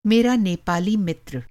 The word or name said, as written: नेपाली